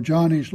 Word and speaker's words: Joni: And yet, in Joni's life, or Johnny's life, is spelled, I think, J-O-N-I, and I usually probably pronounce it wrong. Johnny, I think she goes by Johnny.